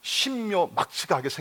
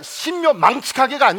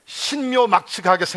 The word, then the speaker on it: 신묘막측: 나는 신묘막측하게 생겼다. 아멘! 발음을 잘하셔. 신묘망측하게가 아닙니다. 한번 따라하십니다. 나는 신묘막측하게 생겼다.